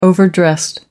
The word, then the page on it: overdressed: American English